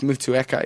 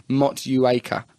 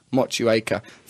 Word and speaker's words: Motueka: By the way, your pronunciation of Mutuaca is hilarious. It's supposed to be Motueka. Motueka.